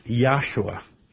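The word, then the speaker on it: Yahshua (יהושע): But of course, his name is pronounced Yahshua. There are no J's in Hebrew.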